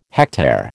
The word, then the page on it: hectare: American English